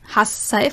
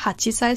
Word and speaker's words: hassei: Nanasei, hassei, Vorsicht, nicht hachisei, sondern hassei.